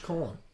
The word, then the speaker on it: Quorn: So do they sell much corn? – No. And well, for those of you, it is spelled you know it's spelled Q. – Yeah, I know. – Q-U-O-R-N.